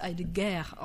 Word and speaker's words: Heidegger: Ja, bei Heidegger übrigens kann man ja noch anmerken, dass der auf Frankreich sehr schön als Heidegger ausgesprochen wird. Und Ger ist der Krieg.